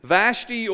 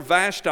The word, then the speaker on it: Vashti: And then when you drop down to verse 9, Queen Vashti or Vashti, I've heard it pronounced both ways, say it how you want to say it, gave a feast for the women in the palace that belonged to King Ahasuerus.